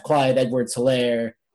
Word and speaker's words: Clyde Edwards-Hilaire: He went to the Kansas City Chiefs, much to my chagrin, as I have Clyde Edwards-Hilaire, the H is silent, on my fantasy team.